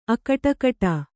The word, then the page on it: ಅಕಟಕಟಾ: kannada